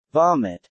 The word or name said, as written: vomit